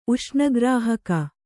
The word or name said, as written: ಉಷ್ಣಗ್ರಾಹಕ